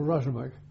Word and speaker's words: Razmak: I was then drafted to one of the outlying stations in a place called Razmak. – Razmak? – Yes. – How do you spell that? I've got it, Dera Ismail Khan. – R-A-Z-M-E-K. – Just exactly as it sounds, right.